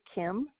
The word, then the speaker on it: Kim: First name is Kim, K-I-M, and the last name is spelled H-O-L-T-M-E-I-E-R.